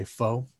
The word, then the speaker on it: pho: And some of people, you know, it pho spells P-H-O. It's yes. – Pho, like kind of like fa la la la la, like pho, like that. – Yeah. – A lot of people mispronounce and say pho. It's not how it's said. It's pronounced pho.